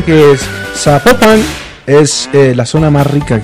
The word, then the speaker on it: Zapopan: Oiga, nos escriben los oyentes y que no, no se pronuncia Zapopan, sino que se pronuncia Zapopan.